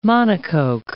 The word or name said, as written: monocoque